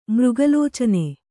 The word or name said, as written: ಮೃಗಲೋಚನೆ